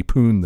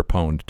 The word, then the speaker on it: pwned: Or pwned, depending on the pronunciation. – Oh, I'm glad to hear it's an 18-year-old kid. – Hey, give me a young person's perspective. Is it pooned or pwned? – We don't say that.